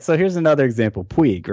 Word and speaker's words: Puig: Well, because if you look at a pronunciation guide for Puig, it's P-W-E-E-G, which is Puig.